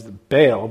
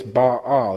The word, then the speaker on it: Baal: So I grew up, everybody pretty much knows it as Baal, but really it's Baal. It's split into two syllables.